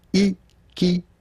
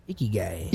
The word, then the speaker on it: ikigai: Ikigai. – Ikigai.